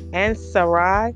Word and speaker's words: Ansaireh: This word is spelled A-N-S-A-I-R-E-H. Ansari, member of the L Order de Lys of France, the double eagle of Grand Dome, met in Paris.